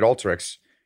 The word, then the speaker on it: Alteryx: And then now you're at Alteryx.